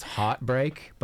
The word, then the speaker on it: HRTBRK: That was Anas Hefni with, I believe it would be pronounced heartbreak, but it's spelled H-R-T-B-R-K.